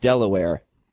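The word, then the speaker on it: De La Warr: It's called Delaware because the captain's last name of one of the first ships to sail to the colonies was De La War, pronounced Delaware.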